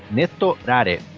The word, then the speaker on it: netorare: Deixa eu ver se eu vou saber pronunciar. Netorare. Neto. Neto Rare. Neto. – R-A-R-E. – É, Neto. Neto Rare. Isso.